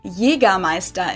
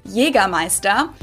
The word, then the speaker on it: Jägermeister: From shoes to alcohol, this is pronounced Jägermeister in German. Jägermeister. – Jägermeister. This is Jägermeister. Jägermeister.